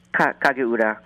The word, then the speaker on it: Kagura: Yeah, Kagura.